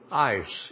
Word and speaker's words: eis: But even if you want to just go with the word believe or faith, the Greek term is pistuin, ice, E-I-S, pronounced like I-C-E.